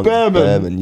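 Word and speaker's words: Bearman: And then we met Oliver Bierman. – Oliver Bierman, yeah. – Ollie Bierman. – Bierman. It is Bierman. – Ollie Bierman. – You can't keep getting this wrong. – Bierman, yes.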